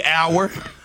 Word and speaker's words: hour: This was another one that threw me hour. – Hour. – Like time. – Yeah. – The H is silent. – Exactly. H-O-U-R, the H is silent. Doubt. – But wait, I'm sorry. Mm-hmm. O-U-R is also hour.